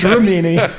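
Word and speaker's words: Remini: And you say her name wrong. It starts with an R, not a K, man. – It's Leah Cremini. Leah. Cremini is a mushroom, I think. It's Rimini.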